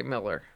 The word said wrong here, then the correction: Millar: Right, Millar. Sorry, Millar. – Oh, is that true? – Yeah, it's like Mark Millar. – Oh, does he actually pronounce it that way? – Millar. Yeah. – Okay, all right. Because I do know. A person... – I know I'm bad with it. – No, but I know a person named spelled that way, but pronounces it Miller like that.